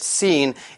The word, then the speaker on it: scene: Let's find out what it means.Right, so the first important thing you need to know about the word scene is that it has that little C in it. It's S-C-E-N-E. We don't pronounce the C in it. It is silent, okay? Scene, not scheme.